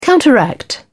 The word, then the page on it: counteract: British English